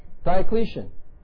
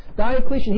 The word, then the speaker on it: Diocletian: The Roman Emperor, Diocletian. Did I say that right? Diocletian. Thank you. Diocletian, he set out just to do this very thing, was to rid the world of Christianity.